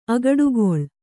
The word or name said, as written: ಅಗಡುಗೊಳ್